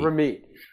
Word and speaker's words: Ramit: Because you want them to think about, you know, meeting, I'm sorry, I'm mispronouncing his name. Is it Ramit? – Ramit.